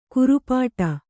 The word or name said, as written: ಖುರಪುಟ